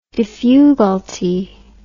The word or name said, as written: diffugalty